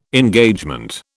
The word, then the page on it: Engagement: British English